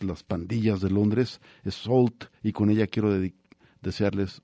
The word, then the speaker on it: Sault: Sault, se escribe S-A-U-L-T, se pronuncia Sault, y es para mi gusto uno de los proyectos más interesantes de los últimos años, muy, muy recomendable.